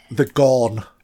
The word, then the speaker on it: Gorn: She noticed the crone egg. Is it how you pronounce it? – The Gorn. – The Gorn egg, that's correct.